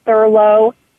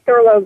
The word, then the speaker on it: Thurlow: I spell that J-A-C-Q-U-I, Jackie Thurlow Lippisch, J-A-C-Q-U-I-T-H-U-R-L-O-W-L-I-P-P-I-S-C-H. Boy, that's a mouthful. So it's Jackie Thurlow Lippisch.